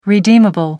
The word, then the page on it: redeemable: American English